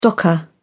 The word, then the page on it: docker: British English